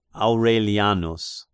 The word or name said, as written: Aurelianus